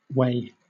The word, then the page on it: whey: British English